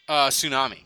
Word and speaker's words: tsunami: Tsunami. – Tsunami. – Oh, okay, because it sounded like you just were trying to pronounce the T in there.